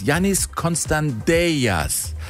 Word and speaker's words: Konstantelias: Ich so, hör mal, ihr habt ja zwei Griechen da und der eine heißt übrigens Yannis Konstantelias. So spricht man den aus.